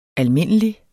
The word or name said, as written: almindelig